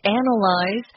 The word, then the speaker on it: analyze: And notice it's a different pronunciation. We use a Z sound, analyze. And analysis is the noun form of the word, and we use a S sound in that instead.